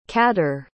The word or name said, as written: kádár